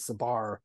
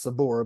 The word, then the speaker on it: Sabar: So it's, and then we'd also discussed how people pronounce it Sabar, but it's actually correctly pronounced Sabor, but you'll accept any pronunciation, right? – Yeah.